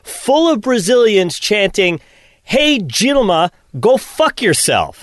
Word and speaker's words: Dilma: It's pronounced Dilma, spelled Dilma, and not Wilma.